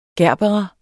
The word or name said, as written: gerbera